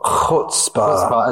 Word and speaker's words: Chutzpah: What was that it described your first, one of your first bands, Chutzpah, or how do you pronounce it? – Well, Chutzpah as folk jazz.